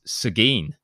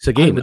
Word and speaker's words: Seguin: It's Seguin. – Oh, love your show. So thanks for loving the show. Sorry for us not being able to pronounce Seguin, but that's Seguin.